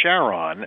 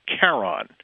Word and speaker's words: Charon: Christie decided that he wanted to name it Charon, as he pronounced it, who is the ferryman in Greek mythology who rode the souls of people across the river Styx into Pluto's domain. Now, proper Greek pronunciation should technically be pronounced Charon,